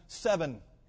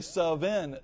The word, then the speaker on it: Sven: They used to call him Seven. Yeah, they did. They called him Seven. They would say Sven, and eventually Sven became Seven.